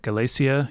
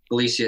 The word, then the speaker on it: Galicia: Galicia. I'm gonna get this. Am I saying that right? Galicia EP. It's oh yeah, yeah that was in Spain. Um, this this region of Spain. Uh, Galicia.